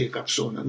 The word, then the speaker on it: Якобсон: Из воспоминаний Александра Жалковского известно также, что студенты Якобсона научились называть его по-русски, хотя и с ударением на первом слоге «мистер Якобсон».